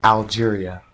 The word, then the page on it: Algeria: English